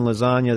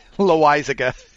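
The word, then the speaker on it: Loaisiga: Wednesday, ESPN at night has Yusei Kikuchi and Jonathan Lasagna, the Yankees. How do you pronounce that name? – Loaizaga. – That's the one.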